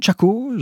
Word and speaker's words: Chaco: Alors, on part du côté du Paraguay, dans la région du Grand Chaco, je ne sais pas si je prononce bien.